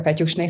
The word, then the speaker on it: Petyusne: Uh, my name is Blanka Petyusne Szecsenyi for the authentic pronunciation of my name.